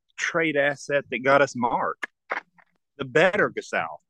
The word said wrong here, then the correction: Gasol: How did I say that? – Yeah, I don't know why you said it like that, Gasol.